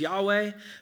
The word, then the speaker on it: Yahweh: The Hebrew underneath it is best pronounced as Yahweh,